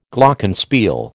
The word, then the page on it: Glockenspiel: American English